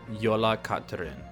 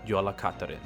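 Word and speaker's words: Jólakötturinn: it's spelled J-O-L-A-K-O-T-T-U-R-I-N-N. Yola Katrin. But the part that sticks out to me in the middle is kat, like it sounds like cat, and this is it's an Icelandic Yule cat or Christmas cat.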